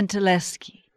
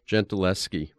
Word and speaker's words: Gentileschi: Yes, Gentileschi. – Gentileschi, who was one of the first well-known women painters.